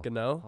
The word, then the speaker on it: Gano: All right, my kicker is, I'm going to butcher his name too, New York Giants kicker Graham Gano? – Gano. Holy, learn how to pronounce it.